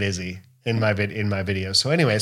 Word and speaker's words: Issy: And it's Izzy, ISSY. So Izzy.